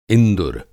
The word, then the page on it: इंदुर: Hindi